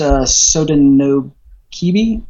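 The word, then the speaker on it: Sodinokibi: Well, I mean, to be correct, it's Sodanokibi. Sodanokibi.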